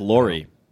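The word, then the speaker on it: Lore: Lohr. Is it Lohr or is it... How is that name pronounced? – I believe it's Lori. – Yeah, I think it's Lori? Lori, like the woman's name.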